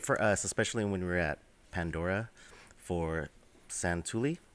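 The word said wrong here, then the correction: Satu'li: Ready? Is that how you say it? – Satuli. – Satuli. – My bad. Satuli Canteen.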